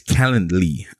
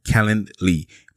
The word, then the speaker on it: Calendly: It's Calendly. I can never say that right. Calendly.